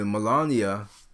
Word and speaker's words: Melania: So Melania, and I I mispronounced her name. I think in my sadness, I kept, I kept calling her Malana. Uh, Malana. I'm not sure. I mispronounced it. But anyway, Melania.